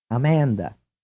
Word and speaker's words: Amanda: Now listen to the girl's name spelled A-M-A-N-D-A. First pronounced in English, then pronounced in Italian. – Amanda. – Amanda. – Amanda. – Amanda. Notice that the vowel sounds in the Italian pronunciation are all vowel one.